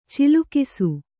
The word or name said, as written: ಚಿಲುಕಿಸು